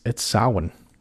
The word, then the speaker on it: Samhain: And you know, the American boy in me keeps wanting to keep saying Samhain, and I know we're still saying it Samhain, but we know from Conal Cochran, and even from our own ancestor, Carrie, it's Samhain. And I'm sure I'm still butchering it, okay?